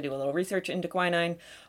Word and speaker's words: quinine: She gave him some quinine powder. I don't know how it's pronounced. – I was always told quinine because my mother took quinine.